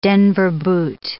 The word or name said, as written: Denver boot